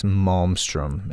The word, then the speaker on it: Malmstrom: It's Malmstrom. – Malmstrom. – It is Malmstrom in Montana.